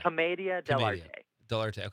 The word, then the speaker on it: commedia dell'arte: Commedia dell'arte.